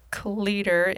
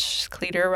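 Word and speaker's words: Clitheroe: Samantha from Cleeder in England. Cleeder rhymes with beater.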